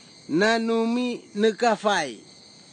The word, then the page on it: nanumi nikafai: kwamera; Tanna